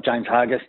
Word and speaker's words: James Hargest: James Hargest, Very good.